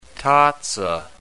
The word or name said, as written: tazza